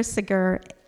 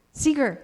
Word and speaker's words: Seeger: It was written by a Baptist minister in the mid-1800s, and it was made popular by Peter Seeger. How do I say his name? Peter Seeger? Seeger, thank you. I knew I had it wrong, in the 60s.